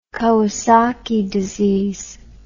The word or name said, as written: Kawasaki disease